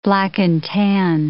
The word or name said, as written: black-and-tan